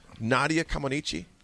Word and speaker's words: Nadia Comaneci: I mean, growing up, there was a gymnast, and Raph, correct me if I mispronounced his name, Nick, Nadia Comaneci.